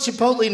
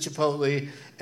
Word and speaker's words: Chipotle: I call it Chipotle now because I heard someone mispronounce it as Chipotle. And it's Chipotle. The Chipotle gift card that Mark Norman put in the prize bag at the New York City show had $0 on it.